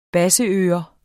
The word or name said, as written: basseører